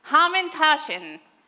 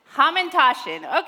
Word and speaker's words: Hamantaschen: Ha- hamashtin. Is Marissa in the room? She knows exactly how to say this word. She's like, oh, hamashtin, or yeah, I don't know. Ham, hama what? Hamintashin. Yes. Say it again. Hamantaschen.